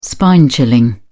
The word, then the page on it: spine chilling: British English